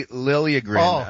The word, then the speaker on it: Liljegren: Timothy Liljegren. Oh, it's actually Liljegren.